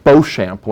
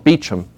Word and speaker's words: Beauchamp: It looks like Beauchamp when it's spelled out, but they call it Beauchamp.